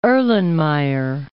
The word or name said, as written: Erlenmeyer